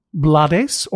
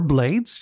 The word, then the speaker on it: Blades: In fact, I was quoting Ruben Blades, or Blades. He accepts both pronunciation.